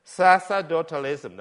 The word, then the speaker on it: sacerdotalism: Sacerdotalism, that's how it's pronounced.